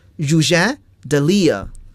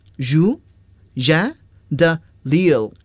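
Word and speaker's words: Rouget de Lisle: 好了，这个词呢是典型的法语词，我们要按法语来发音了。 Rouget de Lille， Rouget de Lille， Rouget de Lille，这是著名的马赛曲的作者。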